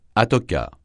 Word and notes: atoca: francais

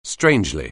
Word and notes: strangely: anglais

